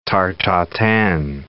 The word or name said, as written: tarte tatin